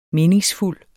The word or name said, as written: meningsfuld